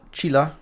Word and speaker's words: Chilla: Und 13, der Song, der danach kommt mit, jetzt frage ich dich nochmal, wie spricht man die gute Dame aus? – Mhm. Chilla? Chilla, ja.